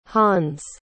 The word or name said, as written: hans